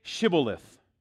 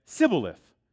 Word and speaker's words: Shibboleth: When he said no, they said to him, "Then say Shibboleth." And they said, he said, "Sibboleth," for he could not pronounce it right.